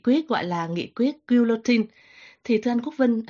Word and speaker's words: guillotine: Trong tiếng Pháp phát âm là guillotine.